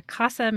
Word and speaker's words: CASA: CASA means. Am I even saying that right, yeah. Yeah. So you yeah, you say casa. being a casa.